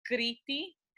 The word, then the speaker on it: Crete: I think we're going to Crete. – Oh, Crete. – Crete, totally off, but thank you.